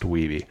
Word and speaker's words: Wiebe: I don't know if this was real or if this was fictional, but until the end of the movie, everyone pronounces his name wrong. They all call him Weeb until the very end. And once he's finally accepted, somebody interrupts and goes, "Hey, actually it's pronounced Weeby."